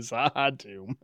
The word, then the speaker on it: Z'ha'dum: We've got season 3, episode 22, Zahadum. Did I say that right? – Zahadum.